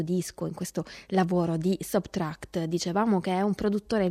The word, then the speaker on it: SBTRKT: Si legge Subtract e si scrive SBTRKT, soltanto le consonanti, proprio a sottrarre.